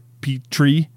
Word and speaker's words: Petrie: Petrie is the pterodactyl, but I think like just the way you say Petrie is like, it's like you pronounce the P, it's like Petrie, like Petit. Whereas like pterodactyl is spelled with a silent P and then the T.